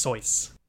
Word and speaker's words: Seuss: Just because you said like a Dr. Seuss looking character, I thought you were just comparing it to Dr. Seuss. But that makes sense if they're Dr. Seuss looking characters that are indeed Dr. Seuss looking characters. – It's actually pronounced Soyce.